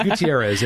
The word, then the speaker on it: Gutierrez: Melanie, my last name is Gutierrez, right? And it's Gutierrez, and it gets butchered all the time.